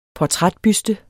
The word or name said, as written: portrætbuste